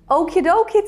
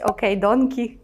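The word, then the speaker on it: okie-dokie: Окі-докі це така собі альтернатива до слова окей. Добре, запам'ятайте вимову, щоб не вийшло якесь окей-донкі чи щось таке. Окі-докі.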